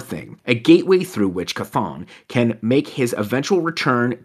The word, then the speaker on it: Chthon: Would you pronounce that demon Chthon? Chthon? – That's how I've been saying it in my head, yeah.